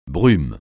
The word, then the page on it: brume: francais